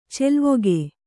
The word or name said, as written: ಚೆಲ್ವಿಕೆ